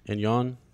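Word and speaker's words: Anjou: So for example, Cersei Lannister and Margaret of, and how do you say that? And Yon? Yeah. – Yep.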